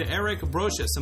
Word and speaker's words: Eric Brosius: On this episode, I am joined by two special guests, Joe Huber and Eric Brocious.